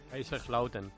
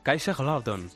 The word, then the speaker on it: Kaiserslautern: El Kaiserslautern, ¿cómo se dice, espera, cómo se dice? – Kaiserslautern. – Kaiserslautern. – Sí, casi como en español.